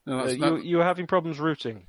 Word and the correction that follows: rutting: No, it's rutting.